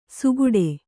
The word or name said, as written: ಸುಗುಡೆ